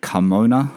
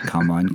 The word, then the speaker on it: Carmona: And his current car, an 05 997 Carrera S Carmona. I've got to make sure I say it right, not Carmine. Carmona, Tony. Carmona Red, very rare, very special car.